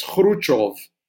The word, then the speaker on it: Khrushchev: But then again, it's not always easy to say something like Khrushchev, for instance, which you can also pronounce as Khrushchev.